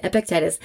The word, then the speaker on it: Epictetus: I've heard that pronounced so many different ways, but that is how I say it, Epictetus.